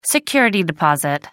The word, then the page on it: security deposit: American English